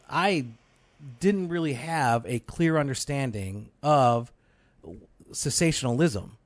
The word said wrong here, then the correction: cessationism: right? Like cessationism. Is that, did I say it right? – You got it. – All right. Joe was like shaking his head at me. – Well, the first time you got it wrong.